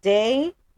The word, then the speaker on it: Dei: Dei, yeah, Dei is the correct pronunciation.